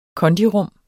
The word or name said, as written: kondirum